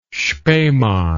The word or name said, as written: spemann